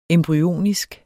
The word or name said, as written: embryonisk